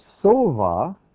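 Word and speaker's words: sova (שבע): But if you pronounce the same word, note the letters are identical, if you pronounce it sova, it means to be satisfied or fulfilled.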